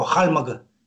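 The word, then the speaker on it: Хальмг: Ну, калмыки произносят его «хальмаг».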